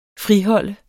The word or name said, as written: friholde